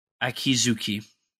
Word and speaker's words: Akizuki: How do you pronounce the family? The Azumi? – Akizuki? – Akizuki. – Yeah.